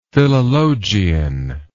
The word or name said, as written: philologian